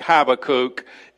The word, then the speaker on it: Habakkuk: And today we're going to start an adventure in the book of Habakkuk or all of the other multitude of ways that you could pronounce it. I like Habakkuk.